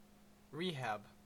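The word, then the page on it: rehab: Canadian English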